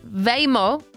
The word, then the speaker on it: Waymo: Waymo diye telaffuz ediliyor.